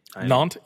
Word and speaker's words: Nantes: Yes, and I believe I crushed that pronunciation. – Nantes? Yes, that is correct. That is the right way to say it.